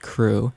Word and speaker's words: CHRU: WXYC would like you to know that the Carolina Harm Reduction Union, known as CHRU, pronounced CRU, is a student-led program that provides life-saving harm reduction resources to the public of Chapel Hill.